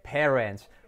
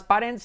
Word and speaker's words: parents: Recuerda, nunca más parents, sino parents.